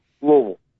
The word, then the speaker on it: Louisville: You really don't pronounce anything. That's the best way to say it. – Okay. You just kind of now is that from the outside or is that with the natives? In native, if you were inside the city of Louisville, would you pronounce it Louisville or Louisville? – Louisville. You pronounce it like there's marbles in your mouth, Coach, and you're talking to a person you really don't want to talk to.